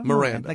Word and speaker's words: Miranda: Miranda. Like the Miranda rights.